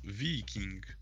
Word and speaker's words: Viking: I'm pretty sure if you want to pronounce Viking football club's name properly, it's Viking.